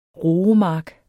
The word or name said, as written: roemark